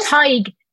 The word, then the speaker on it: Tig: Aoife and Tag? – Tig. Yeah, so it's like, Tig, yeah. Tig, you're close, yeah. So Tig, Aoife and Tig. Tig is like pronounced like tiger without the R.